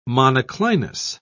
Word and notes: monoclinous: British English

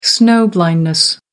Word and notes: snow-blindness: American English

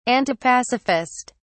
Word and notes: antipacifist: British English

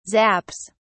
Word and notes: zaps: British English

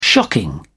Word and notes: shocking: British English

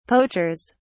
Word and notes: poachers: British English

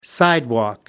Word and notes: sidewalk: American English

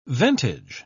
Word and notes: ventage: British English

